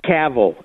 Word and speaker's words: Cavill: It is Cavill. It is Henry Cavill. This is the easy way to remember it. Cavill rhymes with travel.